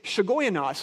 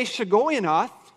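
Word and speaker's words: Shigionoth: It's almost like a transliteration, because what they've done is, if you were to see the Hebrew word, the way that you would pronunciate it is Shigoyanoth.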